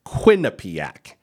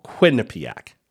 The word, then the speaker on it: Quinnipiac: It's Quinnipiac, emphasis on the first syllable, Quinnipiac.